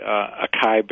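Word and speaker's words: açaí: The acai berry, am I pronouncing that correctly? – I think so, yes.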